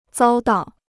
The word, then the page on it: 遭到: zāo dào